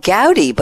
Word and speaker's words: Gavdi: Joining her are two newcomers, John Clifford, group managing marketer at a company called Gaudi, but it's spelled G-A-V-D-I, and we're pronouncing it Gaudi, and we'll find out what they do in a few minutes.